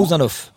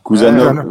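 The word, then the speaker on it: Khusanov: ne parie pas sur le fait qu'on fasse Kouzanov ou Kouranov, je sais jamais comment on le prononce exactement. – Kouzanov. – Kouzanov, voilà. Et Medina un cran.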